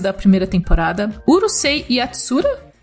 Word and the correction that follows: Yatsura: falei certo? falou, não sei, Yatsura